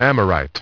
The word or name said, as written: Amorite